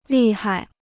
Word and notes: 厉害: lì hai

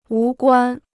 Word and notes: 无关: wú guān

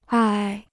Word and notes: 唉: ài

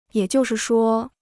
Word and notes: 也就是说: yě jiù shì shuō

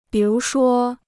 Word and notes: 比如说: bǐ rú shuō

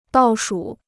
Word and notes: 倒数: dào shù

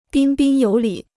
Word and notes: 彬彬有礼: bīn bīn yǒu lǐ

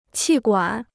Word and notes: 气管: qì guǎn

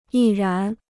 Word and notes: 毅然: yì rán

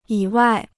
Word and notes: 以外: yǐ wài

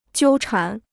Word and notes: 纠缠: jiū chán

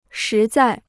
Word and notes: 实在: shí zài

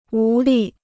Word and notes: 无力: wú lì

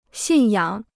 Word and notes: 信仰: xìn yǎng

